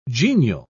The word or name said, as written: genial